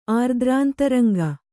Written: ಆರ್ದ್ರಾಂತರಂಗ

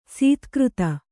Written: ಸದಾಗತಿ

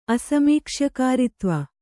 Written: ಅಸಮೀಕ್ಷ್ಯಕಾರಿತ್ವ

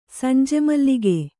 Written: ಸಂಜೆಮಲ್ಲಿಗೆ

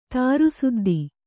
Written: ತಾರುಸುದ್ದಿ

